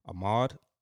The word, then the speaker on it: Ahmaud: Ahmaud.